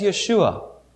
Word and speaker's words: Yeshua: where's Yeshua? That would have been his name pronounced in that day.